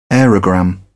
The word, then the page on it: aerogramme: British English